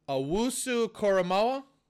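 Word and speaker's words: Owusu-Koramoah: But I'm going edge rusher out of Notre Dame, Owusu Koramoa. Did I say that properly? – Pretty good.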